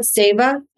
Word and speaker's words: seva: I want to say, I always call it Seva, but I'm from Long Island, New York. So I have a bad habit of mispronouncing things until I'm corrected. So we'll say Siva, we'll say Seva.